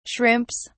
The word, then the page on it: shrimps: British English